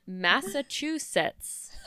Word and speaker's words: Massachusetts: Yes, and Massachusetts, which that is the first time I've said that right, I think.